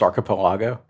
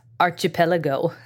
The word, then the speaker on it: archipelago: Isn't it pronounced archipelago? – Not according to this gentleman. It was archipelago. – All right. – And I've always said archipelago. – I think your emphasis is on the wrong syllable if you pronounce it that way.